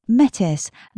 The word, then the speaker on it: METIS: Zoe is Artistic Director of METIS, that's M-E-T-I-S, and collaborates regularly with theatres including the Young Vic, the New Wolsey Theatre and National Theatre and the Royal Shakespeare Company.